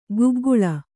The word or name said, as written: ಕಿಗ್ಗಳ